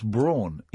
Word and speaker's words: Braun: It's pronounced brawn, Eva Braun.